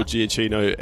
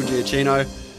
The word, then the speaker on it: Giacchino: Yeah, it absolutely is Michael Giacchino, and Sam loses half a point for spelling it or pronouncing it like that. – Nah, I'm very generous here. You guys are all correct. It is Michael Giacchino.